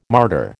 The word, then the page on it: martyr: American English